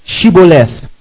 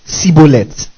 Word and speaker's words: Shibboleth: Then they would say to him, then say what? Shibboleth. And he would say, Sibboleth. Do you get it? They will say what? Say Shibboleth. He would say Sibboleth. For he could not what? Pronounce it right.